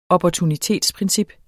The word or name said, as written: opportunitetsprincip